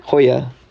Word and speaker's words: La Jolla: La Jolla. – Hoya. – You took Spanish. – I know, but I couldn't, I don't know. Yeah, it's like its own letter in the alphabet.